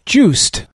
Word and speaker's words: Joost: I wanted to look at what Ricky Inger talked about earlier, an app called Juiced. Not spelled like you would think, but J-O-O-S-T.